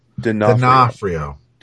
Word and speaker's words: D'Onofrio: Now, how do I pronounce Vincent's last name? – D'Onofrio. Dude, you're Italian.